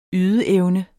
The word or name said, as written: ydeevne